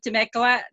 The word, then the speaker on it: Temecula: who is the Economic Development Manager of the City of Temecula. Did I say that correctly? – That's right.